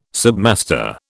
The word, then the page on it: submaster: British English